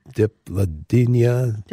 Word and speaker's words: Dipladenia: It is spelled D-I-P. L-A-D-E-N-I-A. – D-E-D-I-P? – Diplodenia.